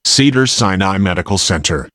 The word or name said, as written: Cedars-Sinai Medical Center